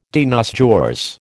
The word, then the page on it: de nos jours: American English